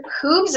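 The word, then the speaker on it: Whooves: Some of Mr. Peter's comic books are Sound Waves, The Supremacy, and Dr. Hooves. Is that how you say it? – Yes. Yeah. Okay. It's a blend of who and hooves.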